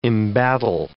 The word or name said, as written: embattle